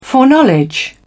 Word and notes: foreknowledge: British English

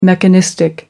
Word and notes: mechanistic: American English

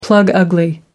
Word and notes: plug ugly: American English

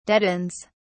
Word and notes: deadens: British English

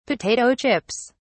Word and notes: potato chips: British English

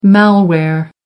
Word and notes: malware: American English

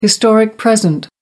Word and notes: historic present: American English

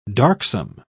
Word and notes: darksome: British English

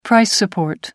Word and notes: price support: American English

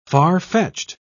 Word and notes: farfetched: British English

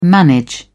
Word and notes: manage: British English

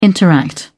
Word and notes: interact: British English

